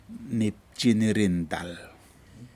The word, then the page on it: nipjin nirintal: Aneityum